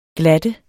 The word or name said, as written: glatte